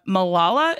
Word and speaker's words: Malala: Another piece of news is the Miss Marvel Twitter account posted a handwritten letter from Malala.